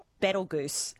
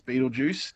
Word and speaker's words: Betelgeuse: I want to say Betelgeuse star. – Good. Very good effort. So yep. So that's one way of pronouncing it. The other one is, of course, Betelgeuse.